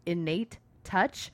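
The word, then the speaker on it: N8 Touch: There's a massage therapy place kind of near our house, and I think how you pronounce the name is Innate Touch, but they spell it N letter 8. Touch. And so we're always like, it's Nate Touch.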